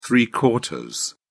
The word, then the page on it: three-quarters: British English